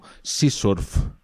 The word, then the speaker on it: CSRF: Sí, me Que, algunos las pronuncian como Sea Surf, de, de surfeo del, del mar, ¿sabes? Sea Surf.